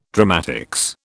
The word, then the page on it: dramatics: British English